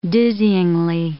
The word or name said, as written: dizzying